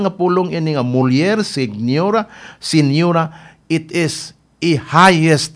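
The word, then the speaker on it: señora: Pero silent G man eh, muna senyora. Mausap sa Spanish, senyora.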